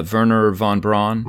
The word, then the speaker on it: Wernher von Braun: And Wernher, how do you say his name? Uh, Wernher von Braun? Correct, yes.